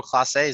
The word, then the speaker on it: Clase: I mean, but if it was Emmanuel Clase, is that how you pronounce his last name? – Yeah.